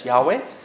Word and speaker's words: Yahweh: Now the aleph represents the four letters yod hey vav hey, which some people pronounce, and myself pronounce it as Yahweh.